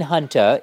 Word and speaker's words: Hunta: No, I mean Hunta. It's like Hunter, but pronounced with a Boston accent, Regina, Hunta.